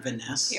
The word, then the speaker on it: Viness: It's Viness. – I like to pronounce it Viness.